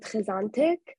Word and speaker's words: Hizontic: Yeah, also I just want to say, like Hizontic for me, I pronounce it Hizontic.